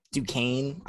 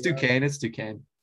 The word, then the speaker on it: Duquesne: FSU beat Duquesne. I don't think I'm even saying that right. They beat them. – It's Duquesne.